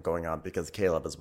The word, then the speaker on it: Chleb: Well, so his name is Caleb Ravenel, although it's spelled C-H-L-E-B. So it's like Chleb. Chleb Ravenel, but it's like Caleb, actually. Caleb Ravenel.